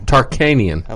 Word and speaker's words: Tarkanian: Jerry Tarkanian. – Say it again. – Tarkanian.